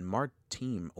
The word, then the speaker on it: Martim: V-I-A-N. Martim. Oh, that's a cool. It's spelt M-A-R-T-I-M. So it's Martim or Martim.